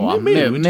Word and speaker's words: mode: Jag skulle vilja säga där att det har lite olika, men vilket mod man är på där. Eh, eller mode på. Mood. Mood, ja, det var ett dåligt uttal där.